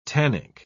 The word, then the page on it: tannic: British English